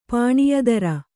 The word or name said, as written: ಪಾಣಿಯದಾರ